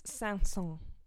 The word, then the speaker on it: Saint-Saëns: Um, so this this piece by Camille Saint-Saëns, um, it's French, and my rule for saying his name is the stupider you feel saying it, the closer you probably are to saying it correctly. – I think it's Saint-Saens.